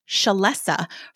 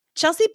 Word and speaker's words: Chelsea: Chelsea is spelled C-H-E-L-S-E-A and pronounced Chelsea. Now, I figure everyone knows how to pronounce this, but my ninth grade math teacher called a girl in my class Shalessa for an entire year.